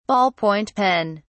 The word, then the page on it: ball point pen: British English